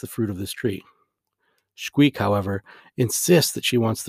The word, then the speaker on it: Xquic: Now the X at the beginning or end of any Mayan word is always pronounced with a sh type sound, so it looks to me like her name should be pronounced Shqeek.